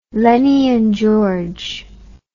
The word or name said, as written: Lennie and George